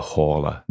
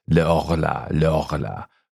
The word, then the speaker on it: Horla: Now, one of the things I've got to say is in French, it is le horla. But I decided to pronounce it with the H because it's a completely made up word anyway. So I can pronounce it how I want.